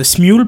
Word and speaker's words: Smule: Alors, ça se prononce euh, Smule, peut-être. Smule. En français, c'est Smule. S-M-U-L-E. – C'est comme Emule Ah ouais. Voilà, c'est ça, comme Emule, sauf qu'il y a un S elle passe de E, exact.